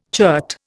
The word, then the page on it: chert: Indian